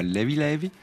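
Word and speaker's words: Leve Leve: Alors volume 2 donc de Levé Levé, qui se prononce là-bas Levi Levi, ça veut dire léger léger, ou tranquillou un peu, en gros, il n'y a pas de stress.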